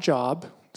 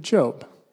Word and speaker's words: Job: Not Job, spelled the same, but Job. And Job has this incredible story.